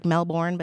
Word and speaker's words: Melbourne: Looks like Melbourne, but they pronounce it Melbourne, Australia.